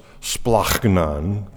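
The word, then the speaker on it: splachnon (σπλάγχνον): The word compassion in the original language is splachnon. And if you say it as it should be pronounced, it's splachnon. That sounds guttural, doesn't it? It's because it's supposed to. It splachnon, or the compassion that Mark is defining or describing about Jesus, comes from the original meaning, from the bowels.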